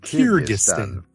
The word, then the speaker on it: Kyrgyzstan: Kyrgyzstan. – Oh, there we go. Let's hear that again. – Kyrgyzstan.